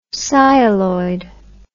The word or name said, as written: sialoid